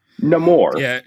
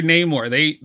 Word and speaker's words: Namor: Namor. – Yeah, it's no longer Namor. They're like, oh, y'all gonna roll your R when you say that.